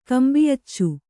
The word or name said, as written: ಕಂಬಿಯಚ್ಚು